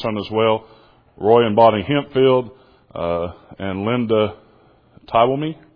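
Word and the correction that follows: Twomey: Did I say that right? Toomey. Okay.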